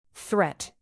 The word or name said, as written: threat